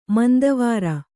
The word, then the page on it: ಮಂದವಾರ: manda vāra